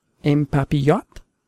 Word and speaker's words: en papillote: And then let's continue on to the second course, which is, now you need to help me with the pronunciation, salmon en papillote? – Yes, very good.